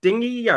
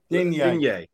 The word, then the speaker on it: Digne: And is it Dingy? Or how do you pronounce his name? Digne. – Digne.